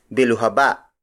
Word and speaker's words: biluhaba: The next shape is biluhaba. Biluhaba. Biluhaba. Biluhaba is spelled as B-I-L-U-H-A-B-A.